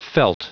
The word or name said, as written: felt